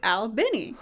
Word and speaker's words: Albany: So, but it's if you talk to people there and you go to the Mayhew? If you go to their big festival, they say it's Albany.